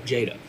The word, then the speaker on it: Jada: Is it Jada or Jada? – Jada.